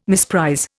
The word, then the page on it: misprize: Indian